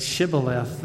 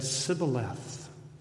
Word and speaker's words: Shibboleth: Now, when people from the tribe of Ephraim said Shibboleth, they just couldn't get how to say it. So they said Sibboleth. The sh was difficult for them.